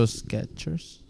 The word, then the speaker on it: Skechers: No T in it. – Yeah. Wow. Yeah. I didn't think it was a T in it. – I pronounce a T. Sketch. – Yeah. Sketchers. Sketch. – So Skechers.